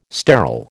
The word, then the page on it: sterile: American English